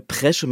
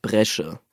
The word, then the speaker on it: Bresche: Ich sage Presche mit P statt Bresche.